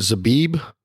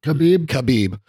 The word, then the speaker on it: Khabib: Is it Zabib? – Khabib.